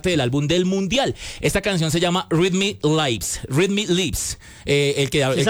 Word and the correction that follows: Read My Lips: ¿Lo leí bien? ¿Lo pronuncié bien? – Read My Lips.